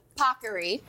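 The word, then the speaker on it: paccheri: This is, as the Today Show team made sure I pronounced properly, Pockery.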